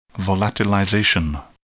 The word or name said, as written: volatilization